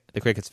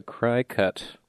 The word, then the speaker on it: Cricut: This whole time I thought that was called a cry cut. – it is spelled like C-R-I-C-U-T, but I think they pronounce it cricket.